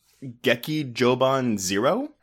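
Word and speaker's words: Gekijōban Zero: So the official title for this movie is Geki Joban Zero? – Yeah, that's basically the right pronunciation.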